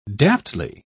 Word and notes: daftly: British English

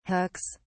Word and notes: hooks: British English

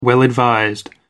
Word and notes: well advised: American English

